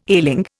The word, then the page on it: ailing: Indian